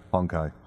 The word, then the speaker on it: Honkai: H-O-N-K, yeah. – Yeah. Honkai.